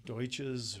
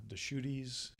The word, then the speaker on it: Deschutes: You know, for heaven's sake, they can't even pronounce it. So, you know, Deschutes or Deutsches or, you know, yeah, Deschutes.